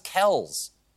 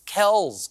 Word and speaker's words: Kelce: According to a discussion on Inside the NFL, the correct pronunciation is Travis Kelce. And in a podcast, Travis's dad backed it up, saying that the proper way to say their last name is Kelce.